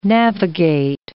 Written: navigate

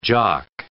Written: jock

